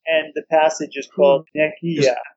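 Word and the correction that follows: Nekyia: I think I'm mispronouncing that, though. – Uh-huh. – Nekya. – Oh, Nekya.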